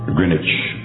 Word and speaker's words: Greenwich: Our prime meridian in the in the world is located in Greenwich. That's how they pronounce it. It's spelled Greenwich, Greenwich, uh, right out of London.